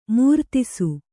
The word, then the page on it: ಮೂರ್ತಿಸು: kannada